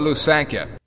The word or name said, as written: Lusankya